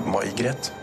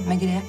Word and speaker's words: Megré: Unnskyld, uh, uttales det May-Gret? Nei, det, det, er et fransk navn. Megré. Grete Megré.